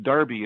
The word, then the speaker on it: Derby: Well, the name Derby is actually pronounced Darby in the United Kingdom, and it comes from Lord Stanley and his family as well.